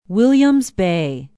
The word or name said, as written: williams bay